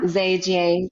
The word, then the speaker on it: Zhejiang: Zhejiang